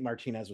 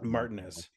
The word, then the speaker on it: Martinez: Say it properly, Mike. Martinez, not Martinez. – Martinez. Say it like an Englishman.